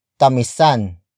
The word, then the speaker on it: tamisan: Word for word it's tamisan mo pa ang kape. Tamisan mo pa ang kape. Tamisan mo pa ang kape. The first word is tamisan. Tamisan. Tamisan. Tamisan is spelled as T-A-M-I-S-A-N.